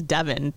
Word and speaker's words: Devon: She goes, you know, technically it's Devon. That's how they pronounce it in England.